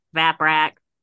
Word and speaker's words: Vaprak: uh, the monstrous god baprak is connected to annam in some myths though he is never regarded as a proper son of annam i'm pretty sure that's pronounced vaprak ba- vaprak